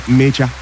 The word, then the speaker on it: Mecha: Then the German, Mecha. Felix Mecha. I have to pronounce it like a Nigerian name because it's a Nigerian name, you know.